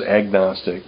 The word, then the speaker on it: agnostic: Gnostic is the Greek root for knowledge, and whenever you put an A, Gnostic with a silent G in the front, by the way. – Right, right. – kind of like gnome.